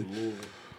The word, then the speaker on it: Louisville: Down there in Louisville. I'm trying to say that correctly. – Louisville.